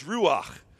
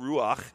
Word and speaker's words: ruach: the main word for spirit is ruach, right? I won't even spell it for you. You can just say, I guess, something in your throat, ruach.